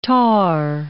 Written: tarre

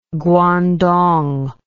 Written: Guandong

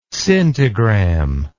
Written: scintigram

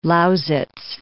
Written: Lausitz

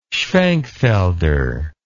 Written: schwenkfelder

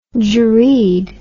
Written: jerid

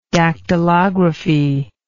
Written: dactylographic

